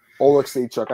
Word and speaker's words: Oleksiejczuk: Oleksiak, because there's this hockey player. It's a hockey player, Jamie Oleksiak, and his sister is like a swimmer, okay. Olympic swimmer. So like that's how they pronounce it. It's kind of spelled similarly, but yeah. – Oleksychuk. I got it right, guys. I actually did it right.